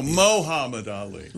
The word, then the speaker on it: Muhammad Ali: No, you said Mohammed Ali. Like Mohandas K. Gandhi. Okay.